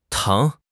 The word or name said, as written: tang2